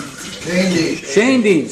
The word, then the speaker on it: Schoendienst: and Red Schoendienst. I should know how to pronounce that good German name. – Schoendienst.